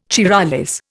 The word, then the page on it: Charollais: Indian